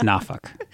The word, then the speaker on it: Norfolk: Yeah, the locals here, they don't use the R. It's Norfolk.